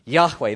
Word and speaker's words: Yahweh: specifically to the Hebrew name Yahweh. I'm not Jewish, but I think that's how you say it. There's a little kind of like in the H. Yahweh.